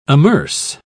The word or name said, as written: amerce